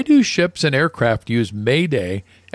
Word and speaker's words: Mayday: Because this comes from the French word m'aides, meaning help me, and is pronounced approximately Mayday.